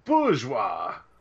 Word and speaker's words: Bourgeois: and the host Doug Tilley actually pronounced it correctly. Hmm. Wow, bourgeois. Oh, nice, bourgeois. nice. – Yeah, it's it's French. Like, there's a lot of silent letters.